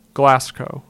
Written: Glascow

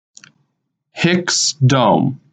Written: Hicks Dome

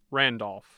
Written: Randolph

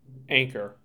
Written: Anchor

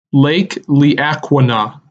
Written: Lake Le Aqua Na

